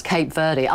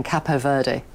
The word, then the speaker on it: Cape Verde: How do you pronounce Cape Verde? I say Cape Verde, but the commentators were saying Capo Verde. – No, Cape Verde. No, it's Cape Verde. Right, okay. That's how I would say it.